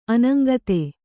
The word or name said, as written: ಅನಂಗತೆ